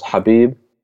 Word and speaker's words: Habib: Well, it's you've got to do the whole ha like sound. It's an Arabic name. So okay. – Yes. So it's Habib.